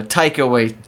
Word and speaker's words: Taika Waititi: Wakai Takiti, is that how you say it? – Oh, uh, Taika Waititi.